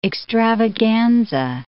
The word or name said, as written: extravaganza